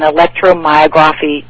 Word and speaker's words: electromyography: And then the neurosurgeon gave me an electromyography test.